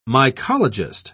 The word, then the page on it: mycologist: British English